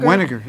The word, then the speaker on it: Weniger: Dr. Brad, is it Weniger? – Weniger, yes.